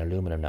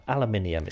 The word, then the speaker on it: aluminium: Uh, I'm saying aluminum now. Aluminium is the proper way to say it.